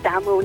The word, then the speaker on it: Damerell: It is French. It used to be pronounced Demerl. – Oh, that's posh, that is, isn't it? – Yeah, the Dempster accent's kind of toned it down a bit. It's Damerl now.